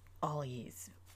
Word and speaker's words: Algiz: I know people pronounce it all kinds of different ways. Bear with me. This is the way it comes correctly out of my mouth, but you really should go with the pronunciation of it that works for you. So, Algiz.